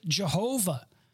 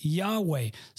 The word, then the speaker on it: Yahweh: And with the added vowels, we pronounce it Jehovah.